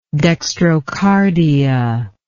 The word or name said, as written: dextrocardial